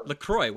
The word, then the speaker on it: LaCroix: Eric says, Adrian, have you tried a pina colada flavor with coconut La Crux? I don't know. How do you say that? Your drink that you're drinking? LaCroix. Why did I say La Crux? I don't know. LaCroix.